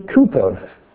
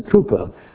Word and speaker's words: Cowper: There's a very famous case of a man called William Cowper, or Cowper if you like, although the correct pronunciation is Cooper.